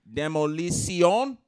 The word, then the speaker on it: Demolición: They're having an event called Demolición. Is that how you say it, Mr. Maroney? – Yeah, man. Your pronunciation slowly gets a little better.